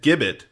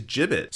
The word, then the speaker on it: gibbet: I repeatedly said gibbet, when the correct pronunciation apparently is gibbet.